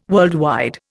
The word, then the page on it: worldwide: Indian